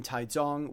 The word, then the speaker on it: Taichung: Look, it's all been so ridiculously fast because not long after that, last weekend I was up in Taizong, which for those of you in Reed Rapids is spelled Taichung.